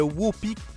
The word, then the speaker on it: Whoopie: Ben, ça ressemble à Whoopie Goldberg, ça s'écrit W-H-O-O-P-I-E. Donc, comme groupie, mais Whoopie.